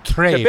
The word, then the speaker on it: Trevi: Oh, Trevi. – Trevi. – Trevi. – Trevi. – Trevi. Okay. Or if you're actually Italian, Trevi.